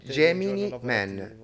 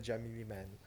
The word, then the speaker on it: Gemini Man: Gemini Man. E io non posso sentire Jamie Man. Pronuncialo bene.